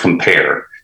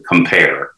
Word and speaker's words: CompAR: We have another product called, it's pronounced Compare. It's spelled CompAR, so it's pronounced Compare.